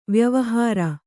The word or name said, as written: ವಾಯುವಿಹಾರ